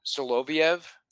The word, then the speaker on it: Soloviev: Soloviev? – Yeah.